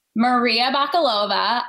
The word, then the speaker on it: Bakalova: Maria Bakalova.